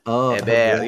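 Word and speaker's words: Héber: We could get Freddy Montero, or we could get somebody that we've already faced, Heber, from former NYCFC striker. – Heber. Yeah. I mean, that's how he is. La France. Heber. – Oh. – Heber. You know, like Thierry Henry. Heber.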